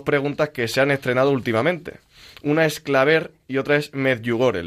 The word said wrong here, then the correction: Clavet: Sí, bueno, el que has dicho regular es el primero porque no es Claver, sino Clavet.